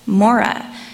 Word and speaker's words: Marah: The Syriac translation of Exodus 15 shows that Mara was likely pronounced as Mora, which is pronounced like the first half of the name Mormon.